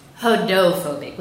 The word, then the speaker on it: Hodophobic: So, I'm Hodophobic with a D.